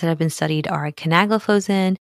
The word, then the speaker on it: canagliflozin: Canagliflozin, dapagliflozin, and empagliflozin. All right. So with that, nice job with the pronunciation.